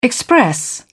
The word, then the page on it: express: British English